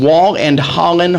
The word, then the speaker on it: Wahl-Holland: They're representing Wahl-Holland Home. Did I say that correctly? – You did.